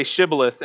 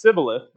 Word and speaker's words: shibboleth: He would tell them to say shibboleth, and if they mispronounced it as sibboleth because of it was how the language was structured at that time, they knew who they belonged to.